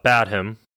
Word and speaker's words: Badham: John Badham.